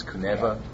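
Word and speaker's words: Kunewa: Yeah, it's K-U-N-E-W-A, I believe, even though they pronounce it with a V. It's Kuneva.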